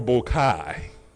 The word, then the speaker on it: bokeh: Is it pronounced bokeh or bokai? – Bokeh.